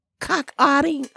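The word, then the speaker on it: cuckolding: Cock-olding. That's what it's pronounced. It's called cock-olding.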